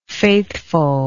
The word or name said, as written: faithfulness